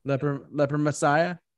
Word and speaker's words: Leper Messiah: Side two was Disposable Heroes, Leper Messiah, I think that's how you say it? – Yep. – Leper Messiah. – Yep.